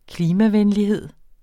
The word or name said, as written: klimavenlighed